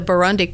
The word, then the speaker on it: Burundi: Last year we had the Burundi Court Drummers.